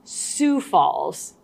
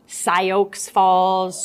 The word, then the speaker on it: Sioux Falls: Well, first of all, I'd say it is in fact pronounced Sioux Falls, not Sioux Falls, Sioux Falls.